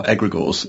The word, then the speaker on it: egregores: You are, egregores and egregorial.